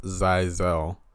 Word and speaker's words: Xyzelle: Her name is Exile. – It's actually pronounced Zyzel. X-Y-Z-E-L-L-E.